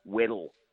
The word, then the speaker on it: Weddell: There's a kid called Josh Waddell, Weddell, in fact, it would be the correct pronunciation, a boy that was picked in that All-Australian side that really is physically ready to go.